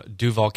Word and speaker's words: Duval: I'm from Duval County. – Is that how you pronounce that? – Duval? – I thought it was like with a longer U. – It can be.